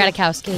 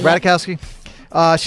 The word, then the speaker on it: Ratajkowski: Ratajkowski. – Ratajkowski.